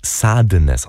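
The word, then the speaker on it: Sadeness: Und ich erwähne ihn deswegen, weil er in dem Song von Enigma vorkommt, in Sadness, aber eigentlich wird er Sadness ausgesprochen, wegen Marquis de Sade, war einer der ersten sexuellen Sadisten.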